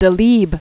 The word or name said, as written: Leo Delibes